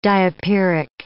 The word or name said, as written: diapiric